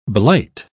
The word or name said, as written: blight